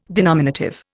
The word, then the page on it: denominative: Indian